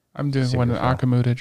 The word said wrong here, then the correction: Akudama: Drive. – Akudama. – I know. – You fucking say it right.